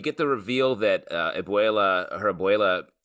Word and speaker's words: abuela: I, for whatever reason, Abuela.